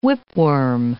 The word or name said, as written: whipworm